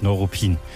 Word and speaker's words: Neuruppin: Ja, die amtlich offizielle Aussprache ist natürlich Neuruppin. Ähnlich wie Neukölln oder auch Neubrandenburg. Die Betonung ist da auf der zweiten Silbe. Insofern, also kurzes Neu.